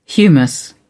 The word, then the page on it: humus: British English